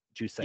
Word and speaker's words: Jousset: I guess he's French, so I assume the T is silent. Jousset.